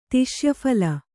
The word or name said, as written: ತಿಷ್ಯಫಲೆ